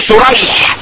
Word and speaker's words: شريح: طيب ومنها ان يتلف اسم الراويين خطا لا لفظا ويتفق اسم ابيهما لفظا وخطا زي ايه شريح ابن النعمان وسريج ابن النعمان. ابوهم زي بعض ده النعمان وده ايه لكن الاول الراوي اسمه ايه الاولاني بالشين شريح بالشين والحاء المهمله والاخر بالسين والجيم الايه المعمى جمع سريج.